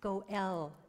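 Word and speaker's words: Goel (גואל): Redeemer, that name, that very name of this sacred place is pronounced Goel in Hebrew.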